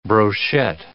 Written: brochette

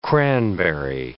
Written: cranberry

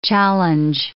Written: challenge

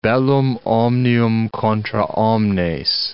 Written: bellum omnium contra omnes

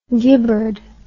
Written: Gibberd

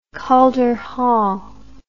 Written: Calder Hall

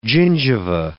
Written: gingiva

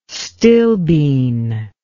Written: stilbene

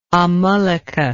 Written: amalaka

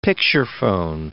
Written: picturephone

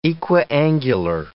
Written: equiangular

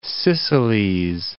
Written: Two Sicilies

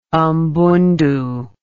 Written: mbundu

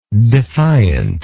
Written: defiantness

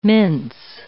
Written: mince

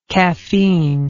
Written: caffeinic

